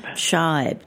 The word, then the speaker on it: Scheib: How do you pronounce his last name? – Scheib.